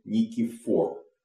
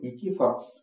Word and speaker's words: Никифор: Кстати говоря, по-гречески, по-моему, Никифор с вот таким ударением. – Но ну, а мы привыкли говорить Никифор.